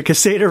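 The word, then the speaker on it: Caséta: And to do that, you need to get their Casetta relay or Casetta. I can see now I'm listening to you and I'm pronouncing it wrong. The Casetta relay.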